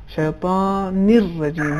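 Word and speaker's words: الرجيم: لہذا اس کا صحیح تلفظ شیطان الرجیم ہے. لام کا تلفظ نہیں ہوگا شیطان الرجیم, ایسا نہیں ہونا چاہیے, چونکہ لام ادغام ہو جائے گا اور لام حذف ہو جائے گا.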